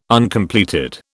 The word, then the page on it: Uncompleted: British English